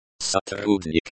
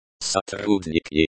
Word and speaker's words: сотрудник: Here, сотрудник is in the nominative case, the subject of the sentence. Variations. Сотрудница. Сотрудница, employee, feminine singular. Сотрудники. Сотрудника, employees, plural. Pronunciation. Сотрудник. Сотрудник, emphasis on the second syllable.